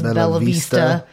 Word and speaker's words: Bella Vista: So I have been saying the city name wrong this whole time. It is Bella Vista.